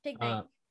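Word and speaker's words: Pignite: So this here is PG Knight. PG Knight? – Pig Knight. – Oh, Pig Knight. You're right. Sorry. Pig Knight evolves from Tepig starting at level 17.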